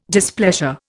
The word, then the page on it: displeasure: Indian